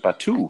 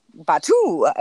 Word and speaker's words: Batuu: I know you recently were a visitor of the planet Batuu. Did I say this correctly? – Yes, that's how they pronounce it. Batuu. – Okay. Batuu.